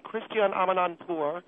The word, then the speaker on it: Christiane Amanpour: I came on your program to praise Christiane Amanpour, which, by the way, that is her name, Christiane Amanpour. You people have mispronounced it.